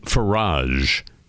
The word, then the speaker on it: Farage: Well, a notorious member of parliament, Nigel Farage, F-A-R-A-G-E, rhymes with quel dommage.